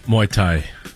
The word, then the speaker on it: Muay Thai: Is it Muay Thai? – Muay Thai.